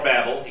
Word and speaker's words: Babel: So the Tower of Babel, or Babel, you can actually pronounce it either way, the people were getting full of themselves, trying to build a tower to heaven and make a name for themselves.